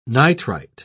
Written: nitrite

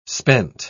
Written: spent

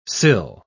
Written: sill

